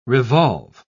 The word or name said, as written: revolve